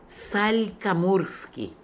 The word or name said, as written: Salkamourskian